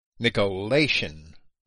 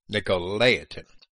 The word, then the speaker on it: Nicolaitan: Nicolaitan is not the proper pronunciation. Nicolaitan.